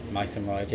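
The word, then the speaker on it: Mytholmroyd: Mythenroyd, which is the town where I live. – Oh, is that how you say it? – Mythenroyd, yeah.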